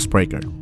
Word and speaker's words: Spreaker: En inglés se pronuncia Spreaker.